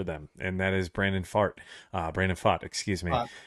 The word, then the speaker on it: Pfaadt: That was the joke at the beginning of the season, Wes, is we didn't know exactly how to say his name, so I was like, it just kind of sounds like a fart in it. That's how it's spelled, P-F-A-A-D-T. It sounds like he farted. So Brandon Fott, he's going to be the guy who's got to set the tone, right?